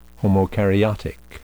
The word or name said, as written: homokaryotic